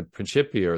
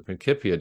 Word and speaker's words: Principia: that people like Newton in writing the Principia or the Principia, depending on your preferred Latin pronunciation,